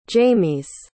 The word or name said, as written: jaymes